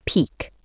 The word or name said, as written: peak